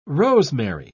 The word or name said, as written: rosemary